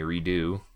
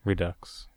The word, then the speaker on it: Redux: Strange Journey Redo. – Redux.